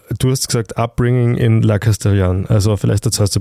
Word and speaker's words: La Castellane: La Castellane. Habe ich das richtig? Castellan, ich glaube. – Ich glaube, also ungefähr so schreibt man es zumindest, das heißt, du wirst ja nicht so weit. Das E hinten ist wegen Scheiße. Fehlenden, genau, ist stumm, weil kein Alexander Gül oder Alexander Graf.